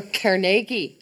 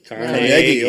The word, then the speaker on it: Carnegie: Indeed. Or Carnegie. – Carnegie. – Oh yeah. – Carnegie. – That's right. Gotta say it right. Say it with that Scottish accent.